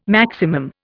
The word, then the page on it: maximum: Indian